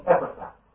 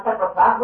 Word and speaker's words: Epaphras: Some call him Epaphras, and some call him Epaphras. Well, they might both be wrong, I don't know, but I generally say Epaphras, but if you think my pronunciation is wrong, well, we're not talking about that.